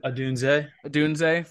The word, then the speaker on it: Odunze: Odunze from Washington.